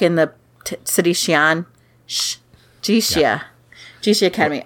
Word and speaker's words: Jixia: Jixia with the X is the sh. So now you know. Anytime you see like a Chinese word, X is shh.